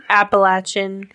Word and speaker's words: Appalachian: Am I saying it right? – Appalachian. – Appalachian.